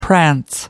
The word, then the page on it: prance: American English